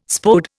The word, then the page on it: Spode: Indian